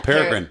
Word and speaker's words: Peregrine: Um, yeah, Peregrine.